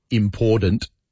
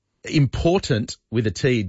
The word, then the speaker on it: important: Kelly's saying, please ask Kel why supposedly well-educated people, even the Prime Minister, say, I have to try to say it incorrectly, Kelly. Important. It infuriates me. Important with a T doesn't seem that hard to say, and yet no, so many people get it wrong.